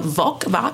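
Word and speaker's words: Voq: Yeah, Ash is interesting because, you know, we were theorizing last week that he was actually the Klingon Vok. How do I say that? How do I say that name? – It rhymes with your favorite body part.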